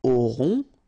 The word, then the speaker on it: aurons: nous aurons, final S not pronounced, O-N-N together, nasal, on, A-U-O,